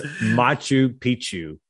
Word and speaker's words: Machu Picchu: It's Machu Picchu. – Machu Picchu. – Yes. Yeah, that's how you say it.